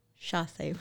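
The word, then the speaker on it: chassé: Tell me if I say this incorrectly. Uh, sachet ball change. – Chasse. – Chasse. Okay, that's close.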